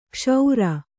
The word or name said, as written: ಕ್ಷೌರ